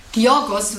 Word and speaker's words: Georgios: Ich weiß nur, Georgos wird es ausgesprochen, der Vorname, weil er Grieche ist.